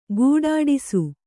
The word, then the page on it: ಖಡ್ಡಿಸು: kannada